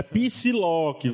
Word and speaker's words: Psylocke: Na época do jogo do Super Nintendo, escolhia e o próprio Wolverine que eu conheci, ele falava: hey, Psylocke. Chamava ela assim, né? Então, é Psylocke. Vamos pronunciar as coisas direito.